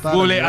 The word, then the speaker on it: Bublé: Hace rato hacía, no sé si es la pronunciación cierta, Michel Bublé. – Bublé, así es, Michel Bublé.